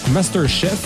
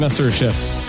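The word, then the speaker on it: MasterChef: Il sera avec nous autour de 16h10 pour venir nous parler de son expérience à Master Chief Québec, Master Chef. J'arrive pas de le prononcer en anglais. On dirait que mais c'est Master Chef. Je vais finir par l'avoir. Évidemment, on va parler de sa passion pour la cuisine et tout ça.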